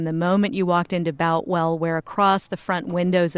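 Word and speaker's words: Boutwell: She joins us now from the historic Boltwell Auditorium, I hope I said that correctly, to give us some reaction. Did I get it right, Tanya? – You know, Tony, we've heard several different ways of pronouncing it today. It's Boltwell.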